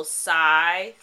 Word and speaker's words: Cy: If y'all are really into the topic, I highly recommend that you follow Cy, C-Y, Wakeman. Mm. It's spelled exactly how it sounds.